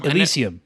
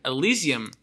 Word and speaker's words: Elysium: You got Elysium or Lysium. – Elysium. – Elysium. – Elysium. – Wow, I got two mispronunciations there.